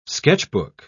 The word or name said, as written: sketchbook